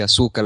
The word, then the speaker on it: Asuka: Vamos con el combate por el título femenino, Asuka contra Nia Jax. – Esta lucha realmente no me llama mucho. Ya sabemos que Asuka, porque así se dice, Asuka, las cosas se dicen como están escritas, Alessandro.